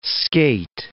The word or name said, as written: skate